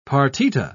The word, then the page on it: partita: British English